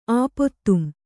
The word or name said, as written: ಆಪೊತ್ತುಂ